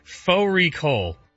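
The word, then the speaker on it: fårikål: Faux recall, which is a mutton and cabbage. It's spelt like far recall.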